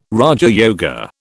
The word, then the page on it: raja yoga: British English